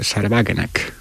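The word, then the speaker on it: Sahra Wagenknecht: es el de Sarwagenek, o como se diga en alemán, ¿no? – Pero está bien pronunciado, ¿eh? – Sí. Bueno, pensé que me ha salido demasiado sajón, pero vale.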